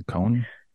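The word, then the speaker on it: koan: There's this Buddhist cone. Is that how you say it? Cone? – Yeah, the cone.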